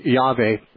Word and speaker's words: Yahweh: Well, Jehovah and Yahweh are actually the same word pronounced differently. – That's what I thought. – Yeah, Jehovah has a J in it and a V in it, where actually in the Hebrew letters, the J sound isn't really there.